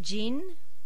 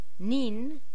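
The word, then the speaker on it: jin (人): And it has three pronunciations. It can be jin, nin, or hito.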